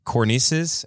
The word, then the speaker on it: cornices: Greco-Roman cornices. Am I saying that right? Cornices? C-O-R-N-I? – Yeah, the things.